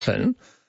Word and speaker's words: Elgin: I mean, it was named after Lord Elgin, and his descendants of him are still called Elgin in the UK. And we've softened the G when it should be a hard G.